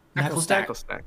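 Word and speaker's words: Naclstack: Knacklestack. – Knacklestack's cute. I love it. – I'll give you mine really quick. I have Knackley in C. I have Knacklestack in.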